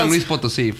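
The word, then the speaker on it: San Luis Potosí: San Luis Potosi.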